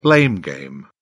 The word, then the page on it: blame game: British English